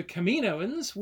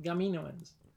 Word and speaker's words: Kaminoans: Um, so the Kaminoans wouldn't have had a K, mm-hmm. They would have had uh, a C, mm-hmm. and it would have been pronounced still pronounced Kaminoans unless you pronounced it Gaminoans, in which case they'd get confused with the Gamorreans, and we're in a different kind of trouble.